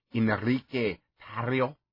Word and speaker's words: Enrique Tarrio: Is that Enrique Tarrio? Oh, I believe that's correct.